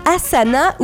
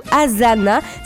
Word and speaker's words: Asana: La première, c'est Asana ou Asana. Ça s'écrit A-S-A-N-A.